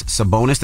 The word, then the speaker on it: Sabonis: Now, that's for stomping, I guess you can say, on Sacramento Kings Domantas Sabonis.